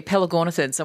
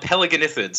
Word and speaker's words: Pelagornithids: I don't want to say that you butchered it, but it's close. It's called Pelagornithids. So you're close with that last one, but I did hear you in the previous segment.